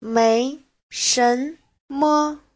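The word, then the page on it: 没什么: mei2shen2me5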